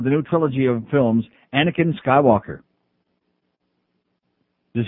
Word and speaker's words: Anakin: Somebody has to keep an eye on their six-month-old son, Anakin. Am I pronouncing that right? – Yes.